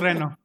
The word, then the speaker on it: Reno: ¿Lo pronuncié bien o no? ¿O es Reno? – No, sí es Reno.